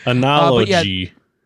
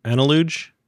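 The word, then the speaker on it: Analogue: We do not know how to pronounce this company. – It's Analog. – It's Analog. – It is Analog.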